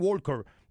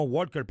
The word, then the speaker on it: Walker: No, Walker, papá. No, la L la lleva. – No, pero no se pronuncia en inglés.